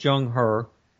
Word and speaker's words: Zheng He: Z-H-E-N-G, capital H-E, second name. It's pronounced Zheng He, and the Chinese treasure fleet.